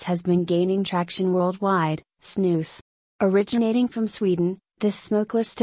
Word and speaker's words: snus: Snus, pronounced snus, is a smokeless tobacco product that originated in Sweden in the 18th century.